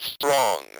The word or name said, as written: strong